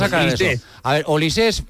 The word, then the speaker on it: Olise: Lleva acento, pero se pronuncia Olise. – Olise. O sea, es apellido francés. – Sí. – Vale, es que no sabíamos si lo de francés era por el padre o la madre. O sea, que es pero es raro que si no lleva acento que se pronuncie Olise. – Claro, ¿no? Si no lleva acento sería Olis. – Es Olis.